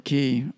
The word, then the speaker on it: Cay: There was an island called Hopetown that was, I was on Man O' War Key, or it's spelled C-A-Y, but pronounced Key, and you know, the Brits pronounce it that way.